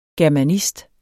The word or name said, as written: germanist